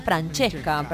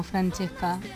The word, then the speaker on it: Francesca: Francesca, pronuncié bien. Francesca, perdón. – Está buenísimo, Francesca. Está muy bueno.